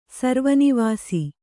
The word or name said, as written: ಸರ್ವನಿವಾಸಿ